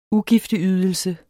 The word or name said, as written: ugifteydelse